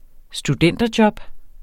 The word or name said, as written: studenterjob